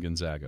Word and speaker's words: Gonzaga: I got UConn and Gonzaga. – In the Elite Eight? – Did I say that right? – Yeah, UConn and Gonzaga.